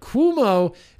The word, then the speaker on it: Cuomo: Cuomo is pronounced Cuomo, for fuck's sake.